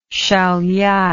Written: siauliai

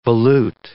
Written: volute

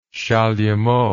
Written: chalumeau

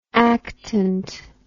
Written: actant

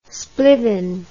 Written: splivin

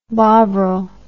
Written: bovril

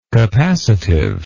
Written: capacitively

